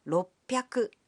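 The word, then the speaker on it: ろっぴゃく: 300 is pronounced sanbyaku. 600 becomes ropyaku. And 800 is pronounced hapyaku.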